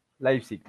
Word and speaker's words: Leipzig: el Manchester United y el Red Bull Leipzig, si no lo digo mal. – Leipzig ok.